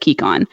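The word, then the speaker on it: Kekon: Magical jade, mined, traded, stolen, and killed for, is the lifeblood of the island of Kikon.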